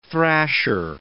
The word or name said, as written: thrasher